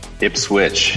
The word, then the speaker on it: Ipswich: Ipswich. – You do pronounce the W.